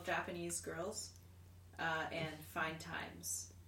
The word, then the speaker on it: JPNSGRLS: It's also spelled J-P-N-S-G-R-L-S. – Okay. But interesting. – It's pronounced Japanese Girls.